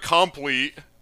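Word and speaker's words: Compleat: I still don't know how to pronounce that. Complete. Yep. I want to pronounce it a little bit different because it's not spelled right, but I think it really just is pronounced complete.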